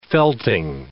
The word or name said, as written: felting